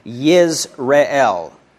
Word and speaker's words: Yisrael: It's actually a word play you might say in the Hebrew, because we have Yisrael, that would be Israel, and then you would pronounce this in Hebrew, the name for this firstborn, as Yisrael. So just speaking that, right?